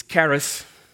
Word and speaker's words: charis: It's charis.